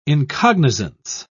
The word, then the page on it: incognizance: British English